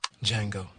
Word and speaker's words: Django: Django. The D is silent.